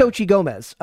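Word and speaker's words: Xochitl Gomez: I didn't know how to pronounce that, but it's Sochi Gomez.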